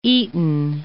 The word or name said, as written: Eaton